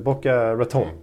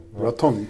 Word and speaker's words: Boca Raton: Boca Raton. Raton. Tänk på batong. Batong.